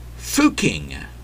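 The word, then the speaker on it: Fucking: It's pronounced, well, it used to be pronounced, Fuking.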